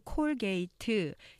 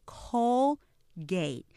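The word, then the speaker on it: Colgate: 우리가 이 치약을 보통 한글로 콜게이트 이렇게 선전을 하기 때문에 예, 사음절로 알고 계시지만 실제 보시면 음절 콜게이트 이렇게 이음절로 되죠.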